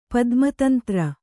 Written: ಪದ್ಮತಂತ್ರ

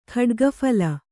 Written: ಖಡ್ಗಫಲ

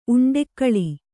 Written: ಉಂಡೆಕ್ಕಳಿ

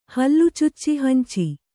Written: ಹಲ್ಲುಚುಚ್ಚಿಹಂಚಿ

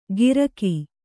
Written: ಗಿರಕಿ

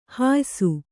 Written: ಹುಯ್ಸು

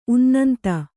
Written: ಉನ್ನಂತು

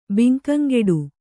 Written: ಬಂಕಂಗಡಿ

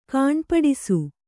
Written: ಗುಣಪಡಿಸು

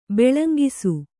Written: ಬಾಲಂಗೋಸಿ

